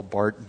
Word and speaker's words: Barth: If you really want to get into it, and I'll give you this as a side note, if you read Karl Barth, B-A-R-T-H, the H is silent because he is German, Emil Brunner, who else is out there?